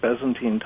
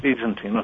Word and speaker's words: Byzantine: So he knew all about the different pictures that were created in since the Byzantine time. I might have pronounced that wrong. – Byzantine, okay.